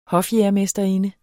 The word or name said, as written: hofjægermesterinde